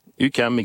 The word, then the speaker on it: UQAM: Mais vous êtes, comment ça se prononce ? UQAM, comment dites-vous ? – UQAM, exactement. UQAM.